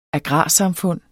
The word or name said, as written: agrarsamfund